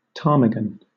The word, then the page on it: ptarmigan: British English